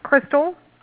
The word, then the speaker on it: Crystal: It's Crystal.